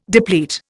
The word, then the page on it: deplete: Indian